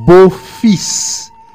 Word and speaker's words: beau-fils: Yerno se dice beau-fils. – Beau, como guapo. Fils, todo es guapo en francés. Guapo y guapa. Beau, B-E-A-U. – Beau-fils. – Fils, F-I-L-S. Se prononce fils. Beau-fils. Fils, F-I-L-S.